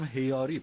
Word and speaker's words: هیاری: هیاری پس تلفظ درست این واژه بود و چه رسم قشنگی است، چه کار دلنشین و زیبایی است.